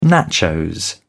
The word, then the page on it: nachos: British English